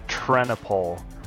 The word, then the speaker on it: Trennepohl: Yes, it's pronounced Trennepohl.